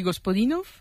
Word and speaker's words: Gospodinov: Ayúdanos a pronunciar los autores. – A ver, Georgi Gospodinov, ¿sí? – Zdravka Eftimova y Vera Mutavcheva.